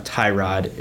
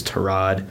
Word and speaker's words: Tyrod: That is how it's pronounced. It's not Tyrod. It's Tyrod.